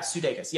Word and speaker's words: Sudeikis: Yeah, Sudeikis.